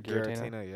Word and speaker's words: Giratina: I'm gonna say Giratina. Giratina. I'm not sure. – Giratina.